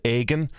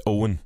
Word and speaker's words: Eoghan: It's spelled E-O-G-H-A-N, and then people pronounce it, I've had Egan, I've had Eggman, I've also got yogurt once as well, but it's actually pronounced Owen. But I was... – Owen, that's it, yeah. Like O-W-E-N.